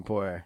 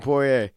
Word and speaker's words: Poirier: Listen, I say his name just Poirier? Dustin Poirier. Everyone's like, no, it's Poirier. And I'm like, gosh, you guys.